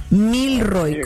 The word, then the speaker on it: Milroy: Milroy, como haga de cuenta como de mil, el número mil, y después Roy, R-O-Y, sí. Pero es una sola palabra, Milroy, M-I-L-R-O-Y, Lane, en Houston, Texas, 77066.